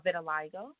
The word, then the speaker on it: vitiligo: It's called vitiligo.